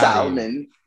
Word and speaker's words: salmon: So y'all don't pronounce the L in salmon. It's what? You put S-A-M-M-O-N. It's salmon. Salmon. – I just have to mentally take the L out.